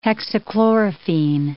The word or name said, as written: hexachlorophene